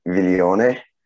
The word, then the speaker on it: Viglione: So the Italian pronunciation, so it's Viglione.